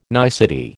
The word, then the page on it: nicety: American English